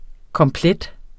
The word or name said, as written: komplet